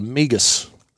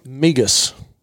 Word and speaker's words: Migas: And it's a one skillet dish called Migas. – What? Say that again? – Migas.